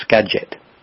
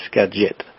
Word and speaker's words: Skagit: It's actually pronounced Skagit rather than Skagit. Git. Okay. – It's spelled Git, but it's Skagit.